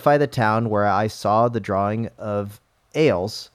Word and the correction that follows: Arles: It's Arles, pronounced Arl, an ancient Roman outpost in Provence, where Van Gogh, pronounced, made some of his most famous paintings.